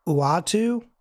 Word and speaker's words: Uatu: I actually have never heard this said out loud, so I'm going to probably butcher this, but Uatu? – Yep. – I believe, because it's U-A-T-U, the Watcher.